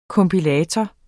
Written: kompilator

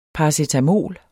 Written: paracetamol

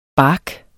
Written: bark

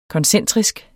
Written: koncentrisk

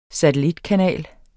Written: satellitkanal

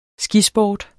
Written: skisport

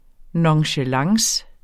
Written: nonchalance

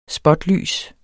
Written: spotlys

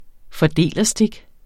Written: fordelerstik

